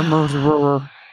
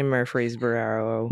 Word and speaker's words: Murfreesboro: And it's all of our corrections for Murfreesboro. – Great. I get to say Murfreesboro.